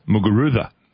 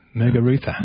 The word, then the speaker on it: Muguruza: Jimmy and Ray, in Spanish, they pronounce Z as TH. So as most newsreaders and sports presenters have been saying, it's pronounced Muguruza. – Muguruza. – Okay. Muguruza.